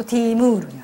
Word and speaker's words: ティムール: でティムールはテムルというモンゴル語をペルシャ風に発音するとティームールになる。